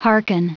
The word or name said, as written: hearken